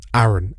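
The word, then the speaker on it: Aaran: Aaron. A-A-R-A-N. I'd imagine you don't have that problem. Unless people try and spell it J-Y-M.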